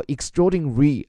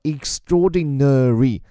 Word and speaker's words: extraordinary: 这句话里最绕舌头的那个词 extraordinary，大家注意它的发音有两种啊，一个呢英国人的发音叫 extraordinary，这个 nary 那个呢。英国人有时候会把它跳过，而美国呢发音比较实诚，所以叫 extraordinary，他们会把每个音节都发到。